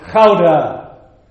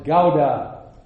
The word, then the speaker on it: Gouda: The Dutchman would pronounce it Gouda. Someone who is not Dutch will invariably pronounce it Gouda.